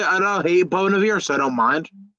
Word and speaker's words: Bon Iver: Yeah, I know Bon Iver. – Bon Iver, that's how you say his name.